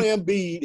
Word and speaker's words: Embiid: Joel Embiid, is that how you say his name? – Yeah.